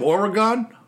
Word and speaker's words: Oregon: Oregon? – Exactly.